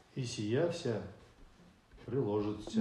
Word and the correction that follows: приложатся: Нет, не приложится. – Приложаться. – Приложаться, да, вот ударение. – Только не ца, а приложаться.